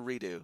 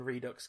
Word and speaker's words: Redux: is it Redux or Redo? I feel like it should be Redo. – I believe you pronounce the X in Redux because it's Latin.